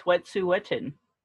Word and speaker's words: Wet'suwet'en: So it's Wet'suwet'en. So you've got it. So you just say it quick, and that's okay.